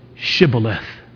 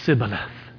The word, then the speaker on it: Shibboleth: And when he said, no, they said to him, then say Shibboleth. And he said, Sibboleth. For he could not pronounce it right.